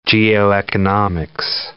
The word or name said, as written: geoeconomics